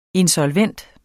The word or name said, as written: insolvent